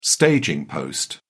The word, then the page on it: staging post: British English